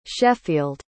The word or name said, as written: sheffield